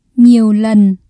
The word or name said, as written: nhiều lần